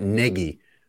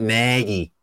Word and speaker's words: Nagy: No one other than Lawrence or Michael Kim on TV pronounces that as Matt Negi, which is how he pronounces it, like rhymes with leggy. Everyone else was doing that, like just Chicago accent, Matt Nagy.